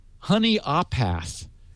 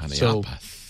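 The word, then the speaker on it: Honea Path: And he helped me pronounce where he lives. It's Honeyapath. And I believe me, from H-O-N-E-A, path, I would not have gotten Honeyapath. – Honeyapath.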